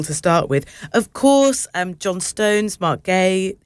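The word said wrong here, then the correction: Guéhi: how do I say his surname? Mark Gahey, yeah.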